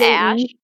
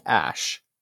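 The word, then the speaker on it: Æ: The Ash. – Yes, the wife said it was pronounced AI, and Elon Musk said it's pronounced Ash.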